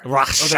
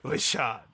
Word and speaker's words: Richard: It's pronounced Richard. – Richard. – Oh, right. Richard.